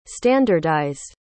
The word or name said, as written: standardized